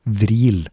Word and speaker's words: Vril: Her må jeg da spesifisere at ordet fril egentlig, på språket til Anunnaki, uttales vril, men at Maria gjorde en tysk vri på det hele, og valgte å uttale ordet med f som v på tysk der, altså.